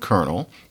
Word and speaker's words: colonel: In any case, this kernel business, the problem with kernel is that I completely understand why somebody would want to know why that word that looks like colonel on the page is pronounced kernel, as in a kernel of corn.